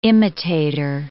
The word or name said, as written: imitator